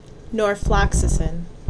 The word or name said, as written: Norfloxacin